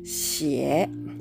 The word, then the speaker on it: 写: 発音記号は XIE の第 3 声低く沈む音になります「しえ」シエこの X の発音はですね比較的口の先の方で前の方で、えー、作るシーンになりますシエ